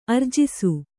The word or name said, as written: ಆರ್ಜಿಸು